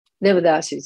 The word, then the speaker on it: Devadasi: Is it pronounced Devadasi? – Devadasi, yes.